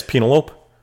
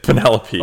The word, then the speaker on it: Penelope: Is that how it's pronounced? – Yes. Penelope. – Okay. Which one is it? – It's Penelope.